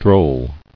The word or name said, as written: Drolleries